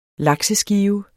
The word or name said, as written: lakseskive